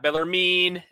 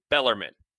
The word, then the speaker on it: Bellarmine: It's not Bellarmine. It's not Bellarmine. It's Bellarmine. Turns out we were both slightly off.